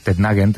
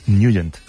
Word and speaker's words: Nugent: Siempre lo hemos llamado en el barrio Tednagent o Tednagent. – Claro, yo era de Tednagent. – Pero la pronunciación correcta es Nugent. – Te fuiste a Washington y descubriste que era Nugent.